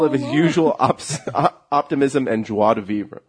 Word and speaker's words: joie de vivre: Joie de vivre. – How do you say that? What the? – Joie de vivre. – There we go.